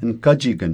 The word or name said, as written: nkajikn